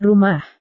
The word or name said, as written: rumah